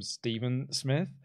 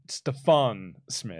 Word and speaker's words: Stephon: When Tony Schiavone mispronounces his name, calls him Stephen Smith, and Taj goes, Stephon Smith.